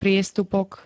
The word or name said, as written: priestupok